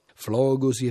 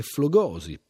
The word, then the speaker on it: flogosi: Non si tratta, insomma, di uno di quei casi in cui la scelta è tra la pronuncia alla greca e la pronuncia alla latina, come invece rispettivamente in ossimoro e ossimoro, flogosi e flogosi, perseo e perseo.